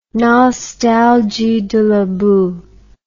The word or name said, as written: nostalgie de la boue